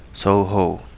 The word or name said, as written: Touhou